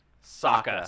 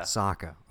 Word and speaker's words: Sokka: Sokka. – Sokka.